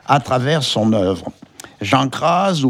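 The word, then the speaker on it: Cras: Et ce nom se prononce crase, avec un Z, SZ, nous précise Michel Fleury, musicologue.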